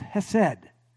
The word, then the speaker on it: hesed: It's the word that's usually pronounced hesed. Hesed.